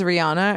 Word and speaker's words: Rihanna: You know, it's funny because everybody says Rihanna and the real pronunciation of her name is Rihanna.